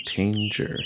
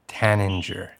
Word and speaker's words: tanager: In my head, I was saying tanger. – Oh, really? In my head, I say tanager. – That sounds like that's the right way to say it.